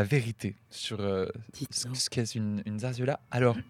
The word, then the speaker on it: zarzuela: Je tiens à dire d'abord que nous avons toutes mal prononcé parce que les deux Z se prononcent et le U se prononce ouais. Donc on dirait plutôt zarzuela.